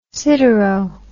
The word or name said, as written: sidero